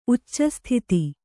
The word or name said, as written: ಉಚ್ಚಸ್ಥಿತಿ